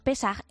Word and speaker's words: Pesach: Pues mira, la Pascua judía, que se llama Pesach, o a veces no pronunciamos nosotros como Pesach, pero el término correcto es Pesach, lo típico es que se coman realmente tres cosas, dos si me apuras.